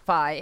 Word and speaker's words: Phi: Someone wrote in, Phi Loli is pronounced Phi, as in Semper Phi, as the original owner was a Marine.